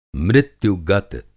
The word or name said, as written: मृत्यु-गत